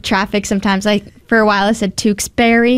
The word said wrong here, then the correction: Tewksbury: Yep. – And someone's like, it's Tewksbury.